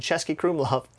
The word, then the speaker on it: Český Krumlov: One of my favorite meals was in Český Krumlov. Don't know if I'm pronouncing that right. – Yes, you are.